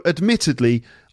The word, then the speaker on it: admittedly: Daily practice is so important in language learning, although admittedly, I don't follow my own advice when it comes to working on my French. Okay. Okay, admittedly, four syllables, it's the second syllable,